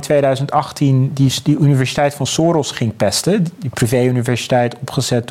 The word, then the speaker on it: Soros: Soros moet ik zeggen, ik moet het wel een beetje nu. Hmm. Nu ik in Hongarije woon, moet ik het wel op zijn Hongaars uitspreken.